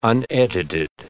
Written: unedited

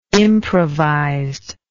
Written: improvised

